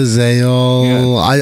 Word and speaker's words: Tzeol: Seol? – Yeah. – Seol to Zeol.